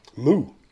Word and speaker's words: MU: See, that's interesting, because I always pronounced it Moo. – Moo. – Moo. – Well, their slogan is party until the cows come home, then Moo.